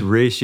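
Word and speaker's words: Rhys: I'm so sorry because I just realized that I've mispronounced your name for 35 years. Because it's Reese Fulber, it's not Reese Fulber. – It's Reese, yeah, because it's a Welsh name.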